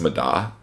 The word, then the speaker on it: Madde: pronounced Mada, not the full thing, but that's what it looks like, M-A-D-D-E, like I would have said Maddie or something like that, Mad, but it is pronounced Mada.